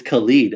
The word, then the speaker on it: Khalid: Also, is it Khalid or is it Khalid? – It's Khalid, and I had to Google it because I wasn't sure how to pronounce it.